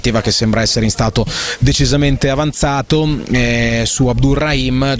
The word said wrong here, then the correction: Abdelrahim: giocatore che non conosciamo, Abdelrahim, chiedo scusa per l'errore sulla pronuncia e sulla dicitura del nome,